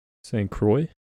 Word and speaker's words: Saint Croix: Saint Croix.